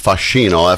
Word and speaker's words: Fascino: It's in Italian, it's pronounced Fascino, F-A-S-C-I-N-O, Fascino. Good word in Italian. It means like charm or allure. It could also mean fascination, but it's really usually used to mean, you know, like il fascino del grande Gaspare, the allure of the great Gaspare.